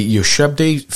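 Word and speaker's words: ushabti: One of the most commonly uncovered types of artifact, the Yoshebde figurine, was designed just for this purpose.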